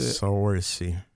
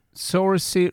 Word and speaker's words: Saoirse: Saoirse. – Saoirse Ronan, säger vi.